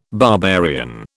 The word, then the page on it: barbarian: British English